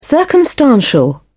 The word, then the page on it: circumstantial: British English